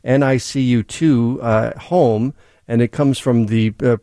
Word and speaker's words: NICU2 Home: And NICU2, that's how you say it. NICU2 Home is how you say it.